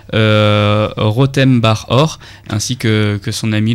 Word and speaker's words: Rotem Bar Or: Et ça s'écrit comment, Rotem Bar Or R-O-T-E-M, espace, bar comme un bar et or comme de l'or.